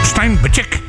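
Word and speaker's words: Steinbachek: Steinbachick, ja, zo spreek je het uit, ja.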